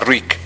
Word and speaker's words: Reek: O sea, Rick, y a lo mejor no han cachado eso por las traducciones, pero Rick es no Rick de Ricardo, o sea, es R-E-E-K, de que apesta, de que huele mal, ¿no? – Rhymes with Mick.